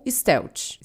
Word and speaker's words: stealth: E uma coisa que acontece no jogo é a opção de matar os clickers no modo furtivo ou stealth. Stealth, na real. A pronúncia é meio treta.